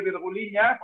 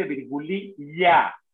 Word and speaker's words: virgulilla: A los gallegos se les deja decir virguliña porque lleva ñ, pero en castellano se dice virgulilla, como pollo. Virgulilla.